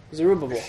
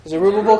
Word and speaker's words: Zerubbabel: Zerubbabel. – Yeah. Zerubbabel comes back first, and then Ezra comes back second, and Nehemiah comes back third.